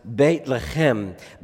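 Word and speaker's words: Beit Lechem (בית לחם): In Hebrew, the city's name is pronounced Beit Lechem.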